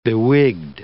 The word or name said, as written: bewigged